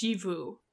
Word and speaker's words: Xxiphu: Pronounced Shivu. Okay. But it's XXIPHU, which is an appropriately non-English name for something from the Far Realm.